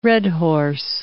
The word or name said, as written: redhorse